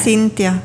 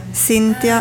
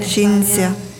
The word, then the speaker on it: Cintia: No es muy común, pero sí es un nombre italiano y es Cintia. Cintia ah, en italiano se dice Cinzia.